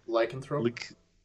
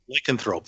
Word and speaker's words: lycanthrope: Oh, Lycanthrope. – Lycanthrope.